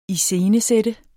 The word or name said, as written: ISCENESAT